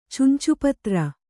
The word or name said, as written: ಚುಂಚುಪತ್ರ